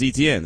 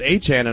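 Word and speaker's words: Etienne: So I would have, Harves, I would have said ETN. Just, I'm keeping it a buck. I just know that how you say it is Achan.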